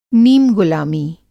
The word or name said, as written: नीमगुलामी